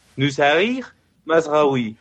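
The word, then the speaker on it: Noussair Mazraoui: Según la señora de, de, la página web esta que te enseña cómo pronunciar correctamente las cosas, es algo similar a Nusair Masraoui.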